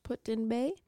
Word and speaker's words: Put-in Bay: So, but it's right off the coast, the west coast of Put-in Bay, which I just looked up the pronunciation of. – Okay. – I hope that's correct. Put-in Bay, which is where our keepers go often for getting off of the island.